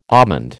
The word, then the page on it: almond: American English